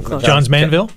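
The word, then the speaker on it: Johns Manville: But like, for instance, the John Mansville, is that, did I say that right? – John's Manville? – John's Manville. So that,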